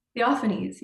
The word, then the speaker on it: Theophanies: So let's talk a little bit about, if I want to say it right, Theophanies? – Theophanies, yeah.